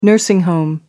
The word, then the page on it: nursing home: American English